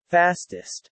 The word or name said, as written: fastest